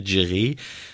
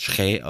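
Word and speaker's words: Kyereh: Maximilian Dittgen vielleicht oder Simon Mackinock ähm, vorne im Angriff neben Guido Burgstaller und dahinter Daniel Kofi Tre da, er hat ja jetzt gesagt, wie er richtig ausgesprochen wird, nicht mehr Giré. Das hat er ja, hat er jetzt im Interview, im äh, Startinterview mit Freiburg hat er gesagt, sein Name wird Schrä ausgesprochen und nicht Giré.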